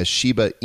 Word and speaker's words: Shiba: And you've probably seen the dog that's associated with, it's pictures of a Shiba Inu. It's pronounced Shiba. It's spelled S-H-I-B-A.